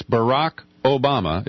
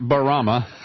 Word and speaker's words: Barack Obama: It's Barack Obama. It's not right. – It's not Barama.